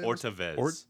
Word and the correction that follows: otra vez: Well, it's spelled Orta. – Is it really? No, O-T-R-A. Otra. – Otra vez.